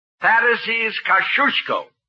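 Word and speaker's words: Thaddeus Kościuszko: In Poland, we say his name Thaddeus Kosciuszko. But in America, Thaddeus Kosciuszko is fine. – Now, please. – Thank you, young lady. Thaddeus Kosciuszko. How's that? – Very good. You say it like you're going to sneeze, and you got it.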